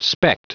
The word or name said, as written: specked